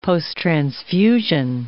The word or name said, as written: posttransfusion